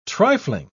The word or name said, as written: trifling